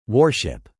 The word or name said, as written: warship